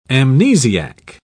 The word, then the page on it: amnesiac: British English